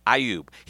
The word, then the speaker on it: Iyoob: And that is spelled I-Y-O-O-B, Ioub.